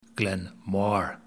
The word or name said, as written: Glen Mhor